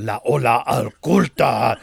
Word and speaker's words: La Ola Oculta: Say it right. You have to say it like a matador. La Ola Oculta.